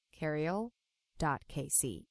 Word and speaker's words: Keriel: which is keriel.kc. It's spelled KERIEL.KC. – Yeah, Keriel. We say Ariel with a K to remember that name.